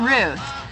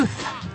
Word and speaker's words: Ruth: My name people have a lot of trouble pronouncing because it's that TH that we have trouble with. So don't forget, stick out your tongue. Th. – Th. – Ruth.